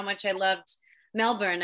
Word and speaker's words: Melbourne: I have been to Melbourne. See how I pronounce that? Not Melbourne, Melbourne.